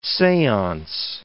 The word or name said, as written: seance